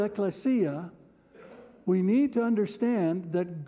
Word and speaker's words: ekklesia: I don't normally do this, but you can repeat after me. Ecclesia. Ecclesia.